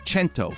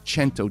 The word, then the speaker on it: Cento: And the company is called Cento, C-E-N-T-O. It's C-E-N, pronounced CH, because it's Italian.